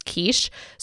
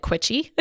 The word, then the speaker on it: quiche: Growing up, Billy did not know how to pronounce quiche. So he called it quichy, which I've always found adorable.